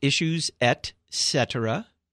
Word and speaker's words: Et Cetera: It is pronounced Issues Et Cetera. Et cetera is the confluence of two Latin words meaning and other things.